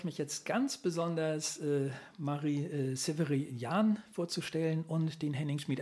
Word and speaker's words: Sepharion: Marie, ist das richtig ausgesprochen, der Nachname? – Leider nicht. – Wie würdest du es aussprechen? – Sepharion. Oh, das ist Französisch, ne? – Ja, das ist eigentlich ein ursprünglich armenischer Name und über Armenien dann nach Libanon in den Frankreich gekommen. Mhm. Und ja, in Frankreich spricht man es halt Sepharion aus.